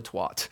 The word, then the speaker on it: twat: I saw, you could just call me Sir Twat. let me see. Rhymes with hat. Twat.